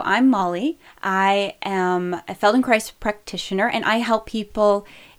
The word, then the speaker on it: Feldenkrais: So, for those of you who haven't heard of the Feldenkrais method, it rhymes with paradise.